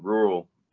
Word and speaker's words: RURL: But I also have a sponsorship with a brand rural around the area, RURL. Pronounced rural but without the A, and they have a lot of, if you like this hat, they got a lot of gear just like this, shirts.